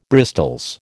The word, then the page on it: bristols: American English